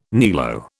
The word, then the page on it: niello: British English